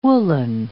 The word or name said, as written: woolen